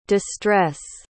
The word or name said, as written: de-stress